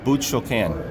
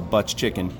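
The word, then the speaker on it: Butz-Choquin: I don't, my French isn't very good, but it's a butch choquin. – Butch choquin. – Yes, or we call it butch chicken.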